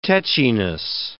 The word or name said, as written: tetchiness